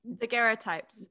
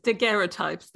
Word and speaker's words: daguerreotypes: Daguerreotypes. – Daguerreotypes, thank you.